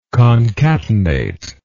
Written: concatenator

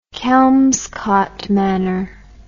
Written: Kelmscott Manor

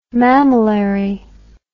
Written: mamillary

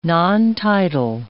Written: nontitle

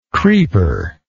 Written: creeper

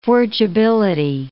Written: forgeability

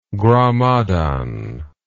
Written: gramadan

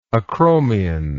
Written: acromion

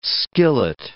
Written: skillet

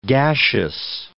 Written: gaseous

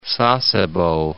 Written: Sasebo

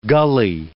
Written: gulley

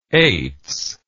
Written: eights

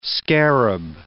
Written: scarab

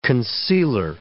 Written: concealer

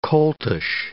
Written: coltish

